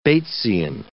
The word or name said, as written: Batesian